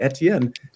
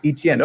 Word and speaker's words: Etienne: But I'm just letting you know the proper way to say Bijan Robinson is actually Bijan, and Travis Etienne is actually Etienne, not Etienne.